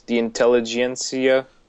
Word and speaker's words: intelligentsia: See, what had bumped him up for me is when he fought the intelligentsia. Those bad guys. – You said that well. – is that how it's said? Anyways, it is. Yeah.